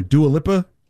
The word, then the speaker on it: Dua Lipa: So here is the Dua Lipa. Did I say your name right, Joe? Because I know you're a fan. Dua Lipa? – Yeah, that's how you say it.